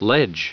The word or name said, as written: ledge